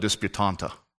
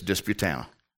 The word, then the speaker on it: Disputanta: It's one of those towns that does not pronounce the way it's spelled. It's spelled Disputanta, but you dare not say that. It's Disputana.